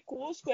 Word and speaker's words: Coozco: É, a pronúncia do aplicativo é idêntica à da cidade de Cusco, né, a antiga capital do Império Inca.